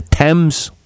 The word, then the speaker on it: Thames: The Thames.